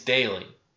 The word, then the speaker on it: Daly: You see, my last name's Daly. – D-A-L-Y.